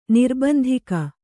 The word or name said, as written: ನಿರ್ಬಂಧಿಗ